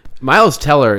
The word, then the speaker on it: Miles Teller: And Miles Taylor. Is that? – Miles Teller is...